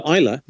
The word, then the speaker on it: Islay: How do you spell Islay? – Yeah. – Islay. – Okay. Islay.